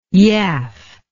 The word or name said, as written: yaff